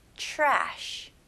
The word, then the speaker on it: trash: 네, 발음은요, 여기서 tr로 시작하지만 여기서는 chr 이렇게 발음하시면 훨씬 더 발음하기 쉬울 거예요. 그래서 trash 이렇게 하는 것보다는 ch 발음으로 시작해서 trash. trash. Very good. 이음절로 하셔야 됩니다. trashy가 아니고요. trash.